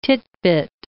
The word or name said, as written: titbit